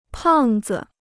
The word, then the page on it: 胖子: pàng zi